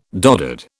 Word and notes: doddered: British English

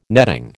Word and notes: netting: American English

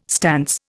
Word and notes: stance: Indian

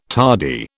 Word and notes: tardy: British English

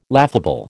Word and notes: laughable: American English

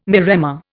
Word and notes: maremma: Indian

